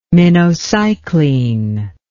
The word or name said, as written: minocycline